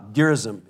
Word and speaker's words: Gerizim: We're going to build a rival temple at Mount Gerizim. Gerizim is the pronunciation.